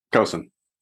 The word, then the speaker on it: Kosen: Kosen.